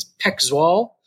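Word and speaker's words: PEC Zwolle: I found out recently from a friend that we've been pronouncing it wrong. The correct pronunciation of Bram's team is Peck Zwall.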